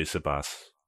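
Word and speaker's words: Siposs: I've seen the pronunciation guide say Sipos.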